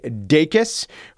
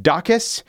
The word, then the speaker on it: Dacus: I do have to correct my pronunciation of Lucy Dacus, who I pronounced, I think, Dacus. I was contacted by a Lucy Dacus superfan.